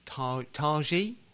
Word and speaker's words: Taiji: And I think it's Taiji, is it? Would it be pronounced Japan? – T-A-I. I can't help you here. – Yeah, T-A-I-J-I. Taiji Wakayama. I would assume that's how you pronounce it. I'm sure that's wrong.